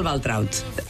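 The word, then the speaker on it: Waltraud: que és el Valtraut. Després et preguntaré si ho he pronunciat bé. – Sí, sí, perfecte.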